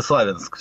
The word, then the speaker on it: Славянск: я не знаю, Славянск правильно или Славянск все-таки. – Даже спорят сами жители города, сейчас спросим у жителей города, как правильно, но вроде бы ударение свободное.